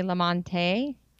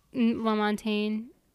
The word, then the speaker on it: Lamontagne: You Are the Best Thing by, I think you pronounce it, Ray Lamonte. – Lamontagne.